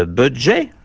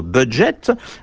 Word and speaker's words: budget: Ce budget, ou plutôt budget, n'était que la prononciation anglaise du français bougette,